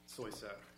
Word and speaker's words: Soyset: Is it Soyset? – Soyset. Yes, Your Honor. May it please the court. Brian Soyset, representing the U.S.